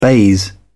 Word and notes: baize: British English

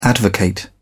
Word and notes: advocate: British English

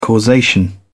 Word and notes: causation: British English

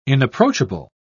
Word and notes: inapproachable: British English

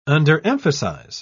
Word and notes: underemphasize: British English

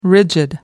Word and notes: rigid: American English

